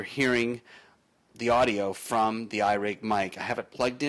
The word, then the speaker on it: iRig: Today I want to talk to you about a unique microphone called the iRig Mic. It's exactly how it's spelled, I-R-I-G. M-I-C from IK Multimedia.